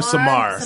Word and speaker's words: Samar: Samir or Samar? – Samar.